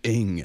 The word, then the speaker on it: Ng: Peter Ng. – Qu'est-ce que ça s'écrit? – I-N-G.